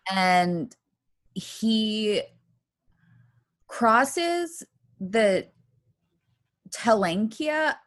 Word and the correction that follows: Teklanika: Oh, I know I said that wrong. Teklanika. Fuck, I do not know. – Good job. – Teklanika, thanks, I sounded it out.